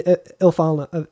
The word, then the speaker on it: Ifalna: Oh, you mean Ifalna? Is how you say that? – Yeah. That's how I say it. – Oh, you mean Ifalna.